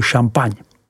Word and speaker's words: Champagne: vyslovuj Champagne.